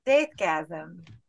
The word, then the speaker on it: Deathgasm: So in case you guys haven't noticed, we are talking about the New Zealand 2015 flick Deathgasm. Did I say it right? – Deathgasm. You're Peter Jackson for the rest of the movie.